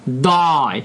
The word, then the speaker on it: dye: 大家知道 dye 就是染料的意思，它跟 die 那个死亡那个单词是一样的发音。